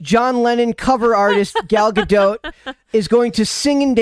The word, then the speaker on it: Gadot: Gadot. The T is pronounced.